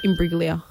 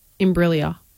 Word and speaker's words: Imbruglia: Imbruglia. – Soft G. – Imbruglia.